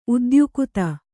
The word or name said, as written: ಉದ್ಯುಕ್ತ